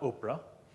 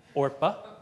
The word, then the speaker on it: Orpah: But what we do find in the exchange between Ruth and Orpah, not Oprah, Orpah, I think I'm saying that right,